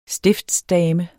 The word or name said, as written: stiftsdame